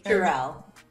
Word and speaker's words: Tirrell: Tirrell.